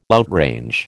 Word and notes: outrange: American English

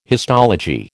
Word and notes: histology: American English

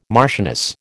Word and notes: marchioness: American English

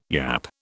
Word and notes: yapp: British English

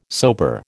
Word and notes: sober: American English

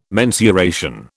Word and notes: mensuration: British English

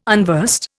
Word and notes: unversed: Indian

